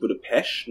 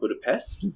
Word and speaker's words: Budapest: and V says that it's pronounced Budapest, not Budapest.